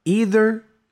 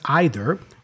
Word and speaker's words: either: Also, what's interesting is the pronunciation, because I think Rafiki in the clip, he says either, which is a possible way of pronouncing it, but you can also go either, depending on the speaker. Yeah? So it might be either or either, but yeah, both are fine.